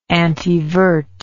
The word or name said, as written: antevert